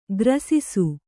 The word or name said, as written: ಗ್ರಾಸಿಸು